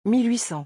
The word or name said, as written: 1800